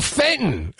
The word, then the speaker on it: Fenton: No, it's F-E-N-T-O-N. – Oh, Fenton.